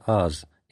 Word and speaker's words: аз: Буква «А», собственно, произносится «аз», это значит «я», то есть «человек».